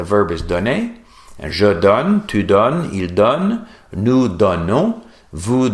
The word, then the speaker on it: donnes: Je donne, tu donnes, with the S, but we don't pronounce it.